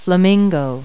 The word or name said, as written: flamingo